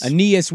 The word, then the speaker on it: Aeneas: Enos Williams. Anias? How do you say his? – Aeneas? – I always thought it was Aeneas. – Aeneas Williams. Thank you.